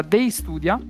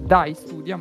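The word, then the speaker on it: Day Studia: Oggi inizierei con lo sponsor, se voi siete d'accordo, perché questa settimana ci sponsorizza Dei Studia, che volendo si può pronunciare anche Dai Studia, ma è scritto D-A-Y come Dei, che è un'ingegnosa app sviluppata da 3 alunni del corso di magistrale di ingegneria informatica.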